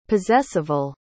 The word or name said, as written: possessival